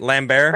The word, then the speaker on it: Lambert: Lambert? – Oh, it's like, because he's French.